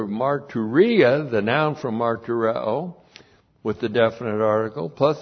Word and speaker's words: martureo: Martureo, M-A-R-T-U-R-E. Long O, martureo.